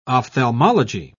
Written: ophthalmology

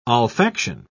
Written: olfaction

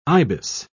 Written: ibis

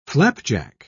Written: flapjack

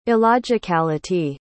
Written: illogicality

